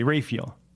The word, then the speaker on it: Raphael: Her last name is spelled Raphael, but they say Raphael. Raphael.